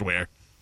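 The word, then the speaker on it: Weare: It's spelled We Are, but it's pronounced Ware.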